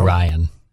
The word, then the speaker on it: Orion: So, did I say it right? – No. Orion. – Oh, I said it wrong again. Sorry. Well, it's like Orion's belt.